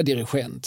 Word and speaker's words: dirigent: För han hade en lång förklaring till detta men det, men det är ju, alltså, skulle man säga dirigent så är det ju, det är ju någon slags franskt uttal och det begreppet förekommer inte i franskan utan det, utan det är ju i så fall liksom det, det, det, tyska uttalet som, mm.